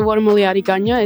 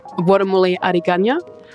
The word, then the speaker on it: Wadamuliyadi Gunya: Yeah, so Wadamuliyadi Gunya is how you say hello in my language. I'm a Birigubba. – Can you say that again for me so I can hear it? – Wadamuliyadi Gunya.